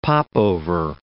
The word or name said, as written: popover